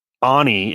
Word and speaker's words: Aune: And Aune is my last name, and it's A U N E.